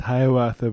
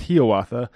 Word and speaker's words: Hiawatha: A lot of people might think it's pronounced Hiawatha, but it's pronounced Hiawatha.